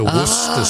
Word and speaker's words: Worcestershire: I thought it was Worcester. – Well, it's typically abbreviated in Britain to Worcester sauce.